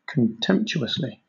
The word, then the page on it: contemptuously: British English